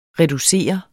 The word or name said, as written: reducere